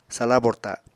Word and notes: sala borta: apatani

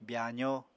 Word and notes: byaazó: apatani

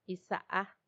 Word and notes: isaa: Macuiltianguis

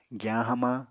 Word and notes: giyañ hamañ: Apatani

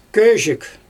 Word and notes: közhik: Xyzyl

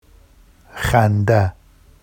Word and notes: xanda: Vafsi

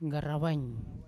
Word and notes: ngarawain: Yawa

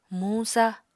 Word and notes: muñ-sa: Apatani

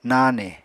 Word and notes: nane: Apatani

